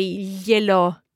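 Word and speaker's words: gliel'ho: because H is mute, to avoid ye, la, o, which is a bit tricky to pronounce. There are two vowels. We can just drop the final A of yela, have an apostrophe, and run it all together and say yelo.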